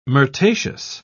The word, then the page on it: myrtaceous: British English